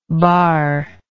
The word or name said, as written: barrable